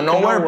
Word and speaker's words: Knowhere: Como nowhere, pero con K. Con K.